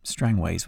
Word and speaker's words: Strangways: But Strangeways is spelt without an E, so it's effectively Strangways when you see it written down.